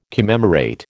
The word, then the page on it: commemorate: American English